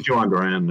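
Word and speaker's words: Jhoan Duran: Is it John, how do I say his first name? – Johan Duran, yeah.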